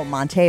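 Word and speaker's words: Montee: Monty Ball. No, Monte Ball.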